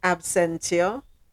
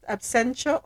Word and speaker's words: absentia: He has been sentenced in absentia. Is that how it's pronounced? Absentia or absentia? How is it pronounced? Where's Sonette to correct me? – Both or either. – It can be either.